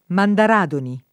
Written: Mandaradoni

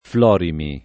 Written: Florimi

